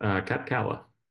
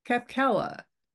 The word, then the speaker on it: Kapcala: Did I say that right? – Kapcala. – Kapcala. – Yep.